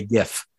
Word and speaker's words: GIF: GIF with a hard G. – Yes. – Not GIF. GIF. – GIF. – GIF. Are you sure on that? – It's like gift, but you took the T off, right? – It's like a little tiny present for you.